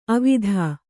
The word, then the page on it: ಅವಿಧಾ: avidhā